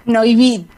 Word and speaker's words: Neuwied: Bueno, estás en una localidad que se llama Neuwied, no sé si lo he dicho bien, o Neuwied, ¿cómo se dice en alemán? – Neuwied. – Vaya, no acierto una.